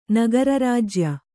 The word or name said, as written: ನಗರರಾಜ್ಯ